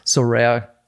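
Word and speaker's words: Sorare: Wird das äh, rare ausgesprochen, ja, ne? – Ja, also je nachdem wo wurde äh, in in das ist ein Pariser Startup, da wird es ein bisschen anders ausgesprochen, aber so rare äh, ja, das wird jeder verstehen.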